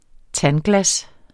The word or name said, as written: tandglas